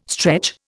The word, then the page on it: stretch: Indian